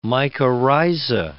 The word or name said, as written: mycorrhiza